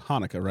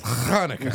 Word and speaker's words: Hanukkah: It's pronounced Hanukkah, right? – Yeah, it's Hanukkah.